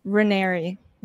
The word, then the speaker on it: Raniere: So, is it Raniere? Is that the pronunciation for Ranieri. – Raniere? Okay. So